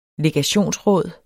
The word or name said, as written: legationsråd